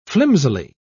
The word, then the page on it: flimsily: British English